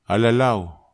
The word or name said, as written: alalau